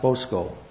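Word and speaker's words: bosko (βόσκω): He uses Strong's word number 1006 for the word feed, pronounced bosko, which means to feed.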